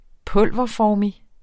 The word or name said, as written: pulverformig